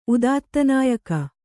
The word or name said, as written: ಉದಾತ್ತನಾಯಕ